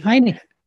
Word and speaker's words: Heinicke: You have Hanky Hanky Henke, Heinken, how do you say his name? Taylor Heinicke. Heinicke.